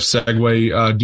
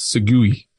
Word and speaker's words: segue: Speaking of segue, do you know how that is spelled? – S-E-G-U-E? – Yes, Segui. – Yes, segue. It's not the segue. – The thing that you ride around.